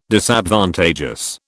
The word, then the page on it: Disadvantageous: British English